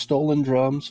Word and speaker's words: STLNDRMS: Then we have track four here from an artist by the name of, I believe it's pronounced Stolen Drums or S-T-L-N-D-R-M-S, but I believe that basically says Stolen Drums.